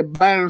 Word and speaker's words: Byrne: Ya que Burn se pronuncia como quemarse,